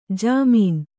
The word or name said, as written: ಝಮ್ಮನೆ